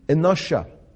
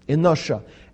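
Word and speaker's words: enosha (אנשא): And the Aramaic term here translated men is enosha, enosha. And it's spelled Aleph from right to left, Aleph, Nun, Shin, Aleph, enosha.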